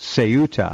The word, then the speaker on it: Ceuta: It's supposed to be pronounced Ceuta.